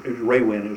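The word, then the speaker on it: rawin: The 400 megacycle transmitters tracked by the SCR 658, the old bed springs, and then the radar wind, Raywind is the right way to say it.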